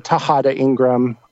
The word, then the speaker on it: Tejada-Ingram: And you can just search that, or really just search my name, Ben Tejada-Ingram, and that's spelled Tejada-Ingram.